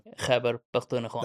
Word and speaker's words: Khyber Pakhtunkhwa: Balochistan, Khyber Pakhtunkhwa. – Yeah, I can pronounce Balochistan, but Sindh, yes, but the Khyber. – Khyber Pakhtunkhwa.